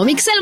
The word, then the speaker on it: Migsel: Así me encuentras, Mixel Montoya. No sé cómo se escribe, mi china. Ahí te va. Pon atención. Atento, porque está complicado. M-I-G-S-E-L. Así me llamo, Mixel Montoya.